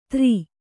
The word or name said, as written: ತ್ರಿ